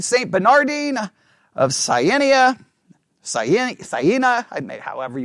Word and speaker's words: Bernardine: Bernardine, yeah, okay, I'm saying it right. B-E-R-N-A-R-D-I-N-E. Bernardine, all right?